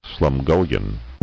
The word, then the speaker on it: Slumgullion: Here's how you pronounce it. – Slumgullion. Slumgullion.